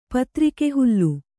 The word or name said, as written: ಪತ್ರಿಕೆಹುಲ್ಲು